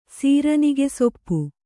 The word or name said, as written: ಸೀರನಿಗೆಸೊಪ್ಪು